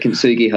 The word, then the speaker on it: Kintsugi: But Kintsugi Hope. I said that right? Kintsugi Hope? – You have, yeah, that's it. Kintsugi Hope.